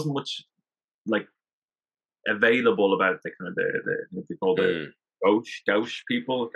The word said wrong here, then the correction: Guanche: I don't know if I'm pronouncing it correctly. – Yeah, the Guanche. – That's it.